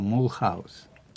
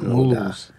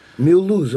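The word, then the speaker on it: Mulhouse: Так, если его читать по букву, называется Мулхаус. Произносится у них Мулуз. – Мюлуз он читается.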